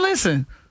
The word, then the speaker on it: listen: Listen is another one where the English words has a silent letter. – Silent and listen. – T. Listen. You don't hit no T in listen.